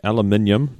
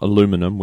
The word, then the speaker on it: aluminium: And basically, they think they've come up with a very fast-charging aluminium, not aluminum. We say aluminium, so that sounds... – We say it right.